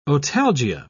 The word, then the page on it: otalgia: British English